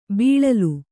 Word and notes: ಬೀಳಿಲು: bīḷilu

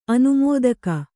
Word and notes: ಅನುಮೋದಕಿ: anumōdaki

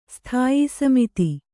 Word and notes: ಸ್ಥಾಯೀಸಮಿತಿ: sthāyī samiti

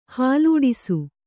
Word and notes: ಹಾಲೂಡಿಸು: Kannada

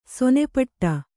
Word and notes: ಸೇನಾಪಟ್ಟ: sēnāpaṭṭa

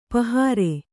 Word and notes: ಪಾಹರೆ: kannada

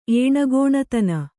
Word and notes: ಏಣಗೋಣತನ: kannada

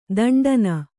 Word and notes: ದಿಂಟೆನಾ: diṇṭenā; Kannada

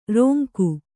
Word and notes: ರೋಂಕು: rōnku

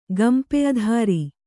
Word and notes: ಗಂಪೆಧಾರಿ: gampedhāri

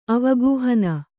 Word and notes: ಅವಗಾಹನೆ: avagāhane

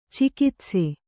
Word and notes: ಚಿಕಿತ್ಸೆ: cikitse

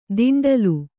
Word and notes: ದಿಂಡಲು: Kannada; diṇḍalu

